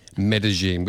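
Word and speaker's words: Medellín: Medellin, which is not how you and I pronounced it last week when it had been released. – No, but that's what she says in the song. It's in Colombia.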